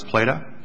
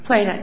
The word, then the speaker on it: Plata: Pinholster took away, I think Brown v. Playa supplied in this case, because Brown versus Plata? – Plata, I'm sorry.